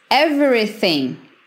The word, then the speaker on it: Everything: Everything. Everything. Everything. Mais uma vez, temos um G aqui no final e não pronunciamos.